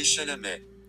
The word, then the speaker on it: Chalamet: Um, Timothy Charlemagne. Charlemagne, Charlemagne, Charlemagne. I don't know how to pronounce his name. Let me let me do it on my phone. Timothy Chalamet. Timothy Chalamet.